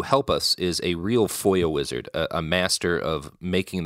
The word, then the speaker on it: FOIA: It's that it's pronounced FOIA.